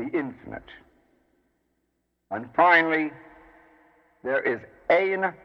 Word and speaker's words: Ein: The first of these three terms is Ein, Ein, Ein, and it means no thing or nothing.